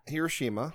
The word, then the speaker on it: Hiroshima: And so the poor cities of Hiroshima and Nagasaki, I might be pronouncing that wrong. – No, that's not. That's not what you. – Hiroshima. – Hiroshima and Nagasaki. – Nagasaki. There we go.